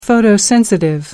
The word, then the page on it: photosensitive: American English